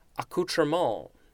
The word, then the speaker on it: accoutrement: Next we have, I'm going to try to pronounce this correctly, accoutrement, A-C-C-O-U-T-R-E-M-E-N-T. I have definitely heard this word. You could also just say accoutrement. I think that would be the English pronunciation.